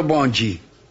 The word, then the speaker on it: bonde: Por la pronunciación en portugués, la gente empezó a llamar bonde, pronunciado bonji.